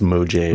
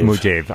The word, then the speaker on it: Mojave: I believe it's pronounced Mojave. – Mojave. I've heard it both ways.